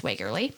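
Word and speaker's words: Wegerle: I had to look that up because it's spelt weird, but it's Wagerly.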